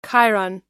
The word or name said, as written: chyron